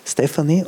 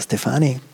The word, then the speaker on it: Stefani: Ihre letzte CD vor den beiden Alben mit Musik von Schenk ist einer schillernden Figur gewidmet, nämlich Agostino Stefani oder Stefani, beide Aussprachen sind gültig.